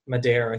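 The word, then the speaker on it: Madar: Yam Madere, I think, is how we've decided to pronounce his name, a.k.a.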